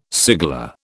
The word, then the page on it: cigala: British English